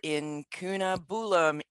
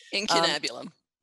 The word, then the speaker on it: Incunabulum: She's the Editorial Director for Incunabulum, Inc. You're probably going to have to correct my pronunciation there. – Incunabulum. – There you go, Incunabulum, which includes award-winning publications, including MG Magazine and CBD Today.